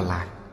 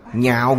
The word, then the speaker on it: nhạo: Chữ này đọc là nhạo, chứ không đọc là lạc. Nhạo nghĩa là yêu thích, lạc là khoái lạc. Chữ này ở đây đọc là nhạo.